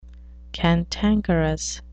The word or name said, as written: cantankerous